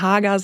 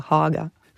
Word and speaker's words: Haga: Ja, also als Deutscher würde man Haga sagen, aber wenn man auf Schwedisch muss man A sagen, O denken, also Haga.